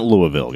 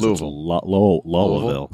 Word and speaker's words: Louisville: I can never say that right. It's not Louisville, guys. – Louisville. – You know, something like that.